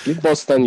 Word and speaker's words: Bostan: A place called a Bostan, B-O-S-T-A-N, Boston. I'm not sure how to say it. – Boston, you said right the first time around.